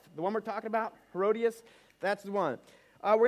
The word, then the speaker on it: Herodias: We have multiple nationalities and we have multiple pronunciations. Tim said, which is the right one? I said, the right one is where you come from. How do you pronounce it? Read. So uh, for, for Tim and our fellow Australians, it's Herodias. I grew up with Herodias.